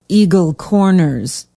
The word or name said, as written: eagle corners